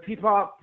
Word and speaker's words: PPOP: Pro- is is PPOP the correct pronunciation of the acronym? – Uh, yeah, so PPOP, Portland People's Outreach Project, uh, it's uh, is a peer run uh, needle exchange and like harm reduction organization.